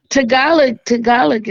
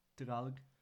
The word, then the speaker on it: Tagalog: Tagalog is very similar to Spanish. – Thank you for pronouncing it right. No one ever does that.